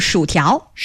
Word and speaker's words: 薯条: By the way, how do you say French fries? – Ooh, 薯条. – 薯条. – 薯. – 薯条. 条. – 条 means long object.